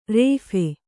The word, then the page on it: ರೋಫು: Kannada; rōphu